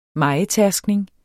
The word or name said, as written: mejetærskning